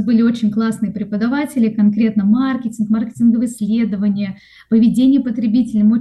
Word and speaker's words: маркетинг: Подавала документы в колледж на управление, на менеджмент, что такое маркетинг, вообще даже не знала. И, кстати, впервые тогда услышала, что маркетинг, а не маркетинг, что в профессиональной среде надо делать именно так ударение.